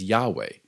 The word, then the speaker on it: Yahweh: Before we get into Elohim, Yod-Heh-Vah-Heh is another name for God often pronounced as Yahweh, yet the Hebrew alphabet doesn't have vowels, and so we only know for certain the consonants and guess at what the vowels might have been. This is why you sometimes hear me pronouncing it Yod-Heh-Vah-Heh, which is literally pronouncing the consonants. For this study, we'll pause on this name, since it appears to be a later addition to the story.